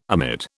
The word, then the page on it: amid: British English